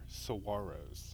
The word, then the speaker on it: saguaros: they are saguaros.